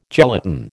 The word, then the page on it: gelatin: American English